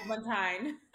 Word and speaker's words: Agbontaen: Agbontaen.